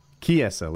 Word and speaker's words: Chiesa: Chiesa, like with a K. Okay, but it's spelled with CH, so it's hard.